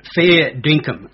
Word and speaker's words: Fair Dinkum: And to correct your pronunciation, that my website's called Fair Dinkum.